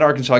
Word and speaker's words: Arkansas: So how do you pronounce it? – Arkansas. – Oh, okay. – Don't say that. Brett Bielema will get really mad at you if you say it the other way.